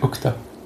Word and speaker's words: Okja: Je coréen c'est Okta.